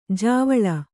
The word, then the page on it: ಜಾವಳ: Kannada